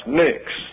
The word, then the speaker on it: Pnyx: Therefore, you have the English translation P-N-Y-X, pronounced Nyx.